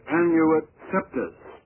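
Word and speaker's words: Annuit Coeptis: On the reverse of the seal, there are 13 rows of stones in the unfinished pyramid and 13 letters in Anuit Coeptus. The English pronunciation of that, by the way, is Anuit Septus.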